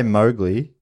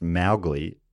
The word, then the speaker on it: Mowgli: You know, we say Mowgli. Apparently, it's actually pronounced Mowgli, and Kipling's daughter never forgave Walt Disney for the mispronunciation.